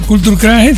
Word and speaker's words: Culture Club: Culture Club se pronuncia.